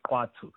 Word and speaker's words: Khwattu: It's called Kwatu, but it's spelled Khwattu, but it's pronounced Kwatu, right?